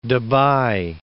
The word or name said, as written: Debye